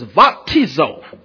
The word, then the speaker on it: baptizo: We utilize the modern Greek pronunciation. And so instead of we say bap, we say vap. There's no B in the modern Greek pronunciation. It's V. So it's baptizo.